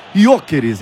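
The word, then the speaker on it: Gyökeres: o chefe mandou Jokeres, tá? – Jokeres. – Com acento circunflexo para ficar fechadinho, Jokeres.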